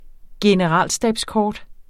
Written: generalstabskort

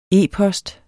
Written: e-post

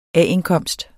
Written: A-indkomst

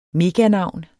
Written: meganavn

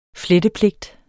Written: flettepligt

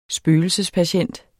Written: spøgelsespatient

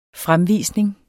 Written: fremvisning

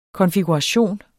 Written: konfiguration

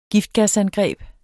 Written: giftgasangreb

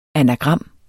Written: ANAGRAMMER

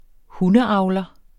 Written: hundeavler

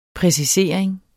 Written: præcisering

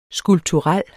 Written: skulpturel

